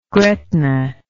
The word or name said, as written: gretna